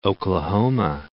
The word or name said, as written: Oklahoma